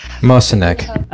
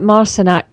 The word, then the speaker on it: Marciniak: Barbara Marciniak, I don't know how you pronounce her name. – Marciniak. – Right, Marciniak. In her brilliant book,